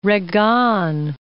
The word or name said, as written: Reggane